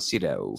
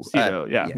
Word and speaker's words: Sydow: Sydow? – I have no... Max von Sydow. – Sydow.